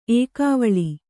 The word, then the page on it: ಎಕ್ಕವಳಿ: ekkavaḷi